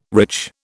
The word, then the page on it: rich: British English